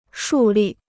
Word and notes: 树立: shù lì

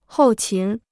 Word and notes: 后勤: hòu qín

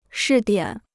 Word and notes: 试点: shì diǎn

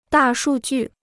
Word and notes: 大数据: dà shù jù

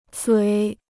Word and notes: 嘴: zuǐ

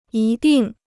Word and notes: 一定: yī dìng

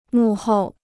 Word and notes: 幕后: mù hòu

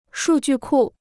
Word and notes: 数据库: shù jù kù

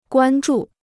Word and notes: 关注: guān zhù

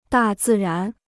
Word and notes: 大自然: dà zì rán

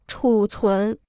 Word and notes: 储存: chǔ cún

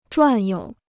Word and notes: 转悠: zhuàn you

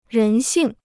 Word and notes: 人性: rén xìng

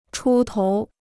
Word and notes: 出头: chū tóu

